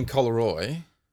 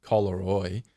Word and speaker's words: Collaroy: So he's got a property in Collaroy. – Is that how you say it? Collaroy. – Yeah, how did you think you said it? – Collaroy. I put the emphasis on the co.